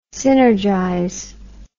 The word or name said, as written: synergize